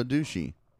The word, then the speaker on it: Doocy: So Biden calls Peter, how do you say his last name? – Ducey. – Ducey? Spoosey. – Ducey? – Ducey. Is it D-O-O? D-O-O-C-Y. – So Ducey.